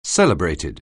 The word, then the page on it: celebrated: anglais